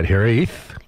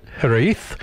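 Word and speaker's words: hiraeth: Well, I can tell you that Hereth. – Yes. – Is the way to pronounce it. Hereth.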